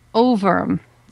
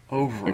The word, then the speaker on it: Ohrwurm: Overm. So it's German. – Overm. – It's actually spelled O H R W U R M.